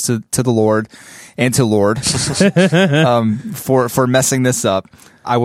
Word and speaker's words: Lorde: Apparently, I pronounced the artist Lord. It's spelled L-O-R-D-E. – Right. – I pronounced her name Lordy.